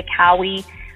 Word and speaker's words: Kaui: And if people want to stay in touch with me personally, you can find me at Cynthia Kaui on Twitter and on Instagram.